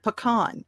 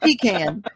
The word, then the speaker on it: pecan: And see, I'm constantly telling people how to pronounce the word pecan. I go, no, it's not pecan. It's not pecan. It's pecan. You're the source. – Well, I'll tell you, it's a Hatfield McCoy thing down in Macon, Georgia, between family, because half my family calls it pecan.